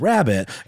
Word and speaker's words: rarebit: And so it's spelled that way, like rare bit, but it definitely is meant to be interpreted as rabbit.